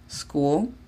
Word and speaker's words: school: La segunda, que significa escuela, la pronunciamos school. Y debe de iniciar desde la S. School, no school.